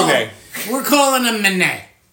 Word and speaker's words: Manet: Edouard Manet. – Yeah, but there's a thingy on top, so it's not Ed. It's listen, it's Manet.